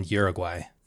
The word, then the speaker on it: Uruguay: This happened down in Uruguay.